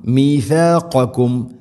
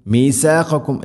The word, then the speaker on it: مِيثَاقَكُمْ: മീഥാഖക്കും മീസാഖക്കും എന്നല്ല മീ സീനാവരുത്.